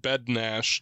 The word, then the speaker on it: Bednar: Only in the Czech Republic is Bednar spelled said Bednash.